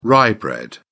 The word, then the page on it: rye bread: British English